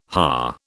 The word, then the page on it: Ha: British English